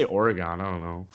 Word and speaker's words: Oregon: Oregon. – Oregon.